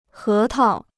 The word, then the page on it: 核桃: hé tao